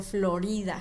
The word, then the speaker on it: Florida: en español. Florida, Florida. En español se pronuncia Florida.